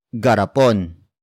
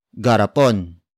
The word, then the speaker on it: garapon: It is the focus marker that introduces the main topic of the sentence, and that is garapon. Garapon. Garapon. Garapon is spelled as G-A-R-A-P-O-N.